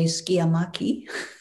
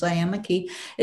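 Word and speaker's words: sciamachy: And in Greek, they say skiamaki, but in English, it's pronounced Cyamaki.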